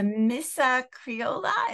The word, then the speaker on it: Missa Criolla: Let's talk about what people should be listening for in, let's just take the Gloria from the Missa Criolla. Is that, did I pronounce that correctly? – Yeah.